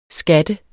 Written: skatter

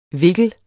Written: vikler

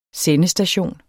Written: sendestation

